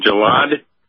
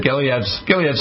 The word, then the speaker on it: Gilead: You're saying Gilead? – Yeah, Gilead Sciences. – Is that how it's pronounced? Okay, sure. – G-I-L-E-A-D, Gilead.